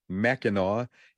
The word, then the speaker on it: Mackinac: traveling under the Straits of Mackinac, or Mackinac, Mackinac is the way I pronounce it, through Michigan's Lower Peninsula and on to refineries in Sarnia, Ontario.